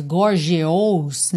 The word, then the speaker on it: gorgeous: Bom, aqui nessa linha, gorgeous é a palavra-chave, porque se escreve G-O-R-G-E-O-U-S. Então, você poderia pensar nela mais gorgeous, né, mais desenhada. Só que não, esse E-O-U vai soar como a, gorgeous, gorgeous, ok?